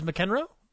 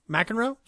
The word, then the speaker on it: McEnroe: saw Borg versus McEnroe. McEnroe, sorry, didn't say it right.